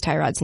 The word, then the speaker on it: Tyrod: So, Aditi Kinkabwala from NFL Network tweeted that Joel Batonio from the Browns is on a mission to get people to pronounce Tyrod's name correctly because Tyrod's too nice to correct people. And she wrote in her tweet that it's capitalized T-U-H dash lowercase rod.